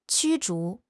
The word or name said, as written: 驱逐